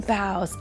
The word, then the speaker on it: boughs: And bough is spelled B-O-U-G-H-S. Boughs.